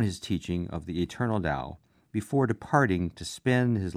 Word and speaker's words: Tao: The Chinese word Tao, which is spelled T-A-O but pronounced D-O-W, literally means way.